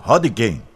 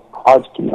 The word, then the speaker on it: Hodgkin: Hodgkin. – Hodgkin. A gente tem dois tipos de linfomas. A gente separa em duas grandes famílias, o linfoma de Hodgkin e o linfoma não Hodgkin.